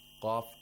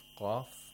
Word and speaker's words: qaf: Qaf has to be pronounced thickly. It should be voiced. It has jahr.